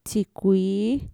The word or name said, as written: txikuii